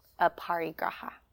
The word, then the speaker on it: aparigraha: And so aparigraha, and if you're the note-taking type, I'll spell it for you, A-P-A-R-I. G-R-A-H-A, aparigraha. Aparigraha is talking about non-hoarding and non-grasping.